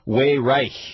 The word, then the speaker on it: Weyrich: Somebody, Elijah in the chat room, he says it should be pronounced Weyrich.